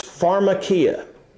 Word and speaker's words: pharmakeia: This word sorcery, number two, the word sorcery is pharmakeia.